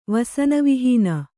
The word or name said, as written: ವಸನವಿಹೀನ